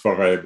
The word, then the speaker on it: Forest: Still pronounced Forêt, but written Forest. – Okay. – Yeah, that's just a quirk of Vincent Dovisa for some reason, pronouncing it that way.